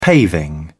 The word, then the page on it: paving: British English